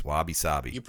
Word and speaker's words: wabi-sabi: This is one of my favorites, wabi-sabi.